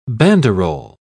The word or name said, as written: banderole